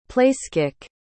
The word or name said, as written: placekick